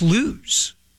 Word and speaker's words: Lewes: No, it's Lewis, Delaware would not be a person. – Okay. – That would be a beach town. Okay. And this person looked at me and said, no, it's pronounced Lewes. L-E-W-E-S.